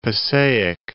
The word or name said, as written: Passaic